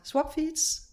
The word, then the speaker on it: Swapfeeds: Jeg har fået lov at besøge Jonas Nygaard fra Swapfeeds. Er det rigtigt udtalt? – Det er helt korrekt.